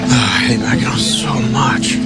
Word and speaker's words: Mackinac: It's spelled, why do they spell it Mackinac when it's Mackinac?